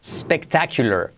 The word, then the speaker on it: spectacular: Number 17, número 17, como en español no consume recursos, espectacular, pero quitando la E delante, spectacular, spectacular, ¿vale?